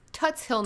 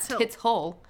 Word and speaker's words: Tuts Hill: It's Tuts Hill, not Tits Hole.